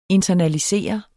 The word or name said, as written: internalisere